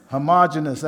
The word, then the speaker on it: Hermogenes: This thou knowest, that all they which are in Asia be turned away from me, of whom is Philemon, or Philelagus, and Hermogenes. I'm messing up them names. I listened to them, but I can't pronounce them. Hermogenes, that's it, yeah.